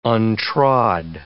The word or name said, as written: untrod